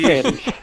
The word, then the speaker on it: Pérez: Guillermo sí. Pérez. – Exacte, exacte, ho has pronunciat bé.